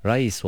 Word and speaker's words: Reis: Thomas Reis olarak telaffuz edilmiyor, Reis olarak okunuyor.